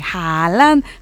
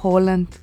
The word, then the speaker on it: Haaland: Me enteré el día de hoy que estamos, pero si bien de que Haaland, y no se dice Haaland, se dice Holland. La doble A en noruego es O.